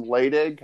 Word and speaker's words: Laidig: We have two special guests, Luke Virgil and Sam Laidig.